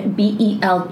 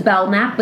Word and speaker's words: Belknap: Anyways, it was just really Belknap, and it was really hard because there's a silent K. So it's Belknap, but it's a silent K in the fucking middle of my name.